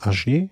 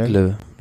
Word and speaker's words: Aigle: Naja, und dann kommt die Etappe am Sonntag äh, von, wie spricht man das, Agier nach Châtel? – Aigle.